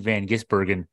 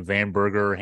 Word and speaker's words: van Gisbergen: The winner on Sunday, a guy named Shane van Gisbergen. Nobody seemed to know how to pronounce his name. The TV announcers, Joey Logano called him Van Burger, Hamburger.